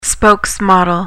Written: spokesmodel

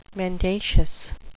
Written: mendacious

